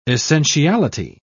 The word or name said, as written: essentiality